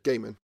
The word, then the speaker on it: Gaiman: Is it Gaiman or Gaiman? – Gaiman.